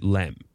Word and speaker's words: LEM: They've removed the excursion, but they still pronounce it LEM.